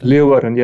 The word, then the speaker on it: Leeuwarden: Leeuwarden. – Leeuwarden.